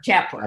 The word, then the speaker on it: Jaipur: Am I pronouncing that correctly? Am I saying Jaipur? Jaipur. – Jaipur. Thank you.